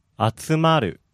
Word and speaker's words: 集まる: The difference between the transitive, which was 集める, and the intransitive, 集まる, is the third syllable.